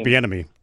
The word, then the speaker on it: Bieniemy: I think the one that caught my eye was Benemini, or I always mispronounce his name. – Eric Bieniemy.